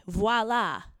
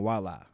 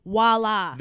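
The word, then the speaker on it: voilà: And he says it proudly and wrong, like, voila. – There are some mispronunciations that I'm okay with. Voila is one of them.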